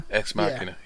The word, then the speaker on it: Deus Ex Machina: Because he yells, I don't know the exact pronunciation of this, what is it, Deus Ex Machina? – Ex Machina, yeah.